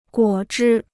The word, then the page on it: 果汁: guǒ zhī